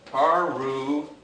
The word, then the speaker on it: parousia: it is parousia. That's an N. And the accent is here. It's not here. I've heard it butchered so many times.